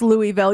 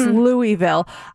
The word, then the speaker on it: Louisville: This one said, I lived in Louisville, Kentucky, and it's pronounced Louisville. Yes, I hear some people call it Louisville. And it's like, no, come on. It's Louisville. This one says, if you know you're a Las Vegas local if you do not go to the Strip.